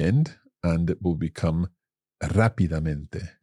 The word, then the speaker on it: rápidamente: Rápidamente. – Now, I have to be honest. I think until this moment in my entire Spanish speaking life, I've mispronounced that word and said rápidamente.